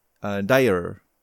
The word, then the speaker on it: Dyre: They're Crackle and Dyer. This, I guess that's how you say it.